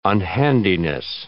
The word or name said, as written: unhandiness